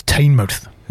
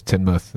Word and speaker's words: Teignmouth: I thought it was Teignmouth. – Yeah, I've always heard it as Teignmouth, and yeah, I'm from Devon.